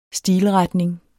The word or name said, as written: stilretning